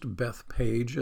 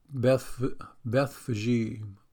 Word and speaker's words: Bethphage: Because it's not really pronounced Bethpage. It's the way I've always pronounced it, the way I've always heard it. But it's pronounced Bethphage or Bethphage. And that makes sense because there's that PH in there, which I don't know why that sounds like an F anyways.